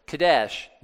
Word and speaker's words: Kadesh: Now, Kadesh means, my mechanic has a tattoo. This is Kadesh. It's a Kof, a Dalet, and a Sheen, and they're It's pronounced, you know, like K-D and S-H, Kadesh.